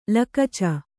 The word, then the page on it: ಲಿಕುಚ: likuca